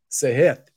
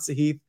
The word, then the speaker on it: Sahith: Sahith. That's how you say it, everybody. I'm sure he's not offended. It's not a big deal, but just FYI, it's Sahith. It's not Sahith.